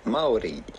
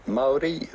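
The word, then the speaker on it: Māori: Well, the natural indigenous way to say it would be Maori. Maori. That's how themselves the Maoris would say it in New Zealand, Maori.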